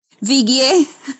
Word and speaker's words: Vigue: Vigue. – Well, okay. Now you're using... – It's French. It's French, Bill. It's Vigue.